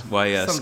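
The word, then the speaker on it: Ys: I always call them Yiz, but I think it's Y-S, isn't it? – Y-S game.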